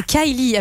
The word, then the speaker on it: Kylie: Kylie. – Ah, Kylie.